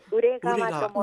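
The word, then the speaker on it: 宇連川: あの、ウレと言って、ウレが、宇宙の宇に連なると書いて、ウレ川と申します。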